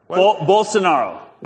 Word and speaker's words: Bolsonaro: Bolsonaro. – Bolsonaro.